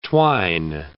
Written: twine

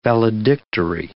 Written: valedictory

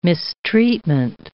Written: mistreatment

